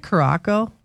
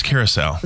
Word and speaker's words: Curaçao: Isn't that Curaçao? – No, it's Curaçao. – Is that how you say it? – Yeah, it's part of the ABC Islands.